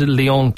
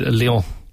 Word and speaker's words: Lyon: Northampton, they beat Lyon 25 points to 14.